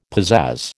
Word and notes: pizazz: American English

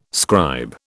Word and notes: scribe: British English

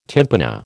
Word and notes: tympana: American English

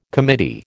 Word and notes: committee: American English

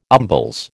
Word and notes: umbles: American English

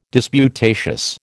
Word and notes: disputatious: American English

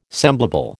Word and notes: semblable: American English

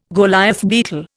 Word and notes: Goliath beetle: Indian

